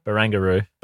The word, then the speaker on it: Barangaroo: How do you say, is it Bangaroo? Bangaroo? What's the? – Barangaroo. – Barangaroo.